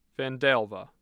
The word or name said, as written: Vandalia